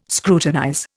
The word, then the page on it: scrutinize: Indian